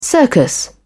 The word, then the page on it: circus: British English